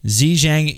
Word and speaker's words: Zhejiang: Zhejiang University.